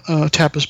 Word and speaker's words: Tapas: Tapas Banerjee, CEO of Webage Solutions. – So spell it slowly for them. – It's T-A-P-A-S, first name.